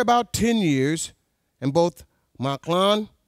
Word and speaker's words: Mahlon: The name of the man was Elimelech, and his wife, Naomi, and the name of his two sons were Mahlon and Chilion. That's how that's pronounced.